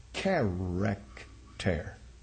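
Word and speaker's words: character (χαρακτήρ): Chi, alpha, rho, alpha, kappa, tau, eta, and rho. And that is character. Character.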